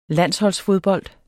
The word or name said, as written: landsholdsfodbold